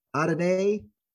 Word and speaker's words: Adeniyi: But again, number 92, Ole Adeniyi. How do you say that again? – Adeniyi. We'll go with that. So I was close the first time.